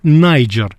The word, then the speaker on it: Niger: Читается это как нигер, страна, и англичане, и американцы тоже так говорили, потому что закрытый слог, соответственно, ай читается, так сказать, закрыто. У меня знакомый... А они это переделали, они переделали да. английскую грамматику, так. и теперь это стали читать Найджер.